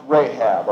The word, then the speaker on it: Rahab: Notice in verse 5, "And Salmon begat Boaz," that's Boaz, "of Rahab," generally pronounced Rahab, or R-A-H-A-B.